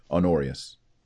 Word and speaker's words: Honorius: H-O, that's how you say it, Honorius. Pope Honorius. It's like honor with eus on the end.